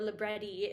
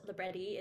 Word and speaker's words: libretti: Librettos, or libretti, if you want to say like the way it might be said in the field, libretti is the Italian plural pronunciation of it.